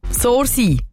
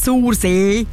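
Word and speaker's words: Sursee: Sorsi. Eben nicht Sorsee. Nein. OGs wissen, das heisst Sorsi.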